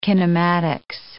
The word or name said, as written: kinematics